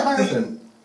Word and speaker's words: Hierophant: Is that what it's pronounced as? Hierophant? – Yeah, Hierophant.